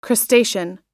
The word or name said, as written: crustacean